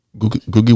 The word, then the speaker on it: Ngugi: Actually, it's Ngugi Watiango. – Googie Wationg. – Yes. – So, we should have Googie coming up. – So the N is silent. – The N is silent. Yeah.